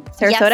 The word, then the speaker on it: Sarasota: Sarasota? – Yes, you did.